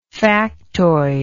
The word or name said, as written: factoidal